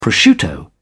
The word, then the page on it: prosciutto: British English